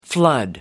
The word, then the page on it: flood: American English